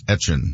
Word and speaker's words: Etienne: And so he said, because he's, back in Louisiana and now playing for the Saints, that he would go by the pronunciation that they would say in Louisiana, which always was like Etienne. – So. – It's Travis Etienne.